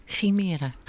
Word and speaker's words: Chimera: In de studio zijn aangeschoven namens Chimera. Spreek het goed uit? – Chimera. – Chimera. De klemtoon moet dus ergens anders.